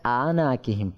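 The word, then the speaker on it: আ-না-কি-হিম (أعناقهم): পরাগুলোর মধ্যে আ এটা আইন রয়েছে, এখানে একটু সতর্ক থাকতে হবে. এটা আনা কিহিম পরে এই আইনটাকে হামজা পরিবর্তন করে অনেকে পরে, এই জন্য এটা আইন, এটাকে আমরা আইনটাকে ভালো করে চাপ দিয়ে উচ্চারণ করবো এবং আওয়াজটা জারি রাখবো.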